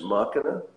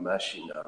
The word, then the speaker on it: machina: I think it's pronounced machina. It's not machina, right? Deus ex machina.